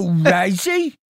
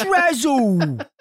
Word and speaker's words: Razzle: It's not Razzie. It's Razzle. Like, as in Dazzle. rhymes with dazzle, mate.